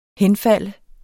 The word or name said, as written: henfalde